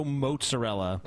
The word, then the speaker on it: mozzarella: Tomato mozzarella. – That's actually the correct way of pronouncing it.